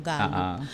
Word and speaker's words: Gallup: Kasi parang siguro ha, ako lang to, hindi ko pa masyadong nabasa lahat yung kabuuan ng Gallup. Tama ba yung pronunciation ko? Gallup or Gallup? – Gallup.